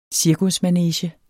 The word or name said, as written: cirkusmanege